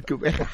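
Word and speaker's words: Gqeberha: Former Port Elizabeth, my pronunciation of course is a little bit low. – Yeah. – What they call it, Kubeha. – Kubeha. – Thank you.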